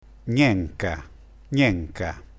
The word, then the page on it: gnenca: dialetto